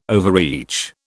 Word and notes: overreach: British English

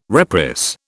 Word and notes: reprice: British English

